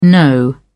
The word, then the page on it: no: British English